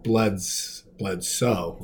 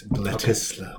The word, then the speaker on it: Bledisloe: Well, most Americans say Bledsoe, like Drew Bledsoe, but it is Bledisloe.